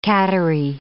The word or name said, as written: cattery